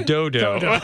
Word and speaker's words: Dodo: Dudu? – No. Okay, the Hebrew says Dodo. Well, that's great. – I think that is the Hebrew pronunciation that Anna had.